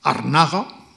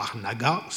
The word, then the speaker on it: Arnaga: Arnaga, o Agnaga, si lo quieren en pronunciación francesa,